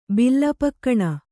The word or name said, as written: ಬಿಲ್ಲಪಕ್ಕಣ